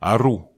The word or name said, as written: aru